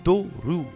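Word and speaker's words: Toruru (トロル): Logiquement, en japonais, le troll se prononce Toruru.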